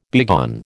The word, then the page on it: begone: American English